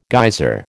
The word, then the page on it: geyser: American English